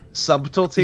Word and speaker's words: subtlety: I just want to say I'm very surprised because you normally pronounce the silent letters in words. – Subtlety? – Yeah, I thought he... I'm kind of disappointed, honestly. – I don't think BT sounds good together.